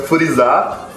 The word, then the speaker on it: Freezer: A pronúncia do freezer, inclusive, é furizar. Furizar e é o nome do freezer no Japão mesmo. Você vai na loja e, e, vai ver um furizar.